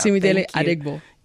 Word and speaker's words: Simidele Adeagbo: Simidele Adeagbo.